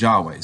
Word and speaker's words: Yahweh: And then we get the YHWH, which we often pronounce as Yahweh.